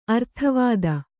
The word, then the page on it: ಅರ್ಥವಾದ: Kannada